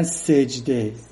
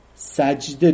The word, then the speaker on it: سجده: از سجود، الف لام سجود، بعضی ها کلمه سجده را اشتباه تلفظ میکنه، میگن سجده، بعضی ها میگن سجده رفتم، سجده درسته.